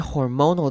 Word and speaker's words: hormonal: 好了，看 hormonal 这个字可能是大家会觉得稍微拗口一点的字，注意到它的重音在 mo 这个音节上面，所以会念成 hormonal, hormonal. 后面的 al 呢，则是念成 ol 的声音，会念成轻音 ol, hormonal, hormonal, hormonal changes, hormonal changes, and of course caused by faulty mood regulations.